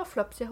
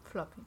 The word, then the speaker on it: Flopsy: Aber Flopsy haut ab. – Floppy. – Ja, aber auf der Hülle steht Flopsy. – Ich weiß, aber es wird Floppy ausgesprochen. Das ist das stumme S.